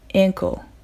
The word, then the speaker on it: ankle: あえてカタカナで発音するとしたら、あいうえおのえの音に近くなります。 ankle, ankle という感じです。